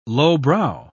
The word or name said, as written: lowbrow